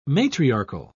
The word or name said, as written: matriarchal